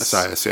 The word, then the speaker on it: Assayas: Assayas, yeah.